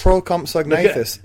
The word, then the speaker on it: Procompsognathus: Procompsognathus is how you say it.